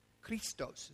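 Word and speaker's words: Christos (Χριστός): Christos is the way it's pronounced.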